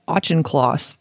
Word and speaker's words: Auchincloss: Okay, so next up we have BP appoints Murray Auchincloss as permanent CEO. – I hope I didn't butcher that. Actually, that's exactly how you say it.